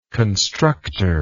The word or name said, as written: constructer